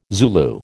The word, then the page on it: Zulu: American English